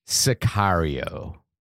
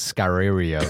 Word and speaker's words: Sicario: Sicario. – Sicario? – I was actually like mispronouncing it as Scarario for a little while.